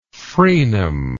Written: fraenum

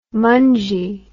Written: mungey

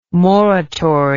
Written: moratory

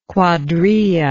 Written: cuadrilla

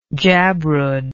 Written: jabrud

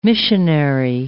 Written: missionary

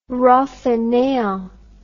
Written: roi faineant

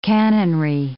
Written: canonry

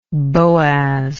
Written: Boaz

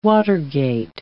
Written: Watergate

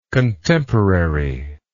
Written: contemporariness

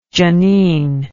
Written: janine

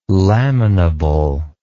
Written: laminable

